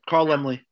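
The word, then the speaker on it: Laemmle: Is that how you say it? Lemley? – Yeah, Carl Lemley. He was the. – My mother always says Lamel. I don't know why.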